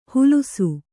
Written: ಹುಲುಸು

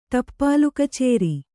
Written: ಟಪ್ಪಾಲುಕಚೇರಿ

